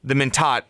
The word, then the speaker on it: Mentat: But just everything with that, like even the Mentat is right.